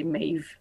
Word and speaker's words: Meave: Ouais, ouais, c'est un peu comme le Yell, comment c'est écrit, le Y-E-A-H. C'est un peu de la même façon qu'on va prononcer Meave.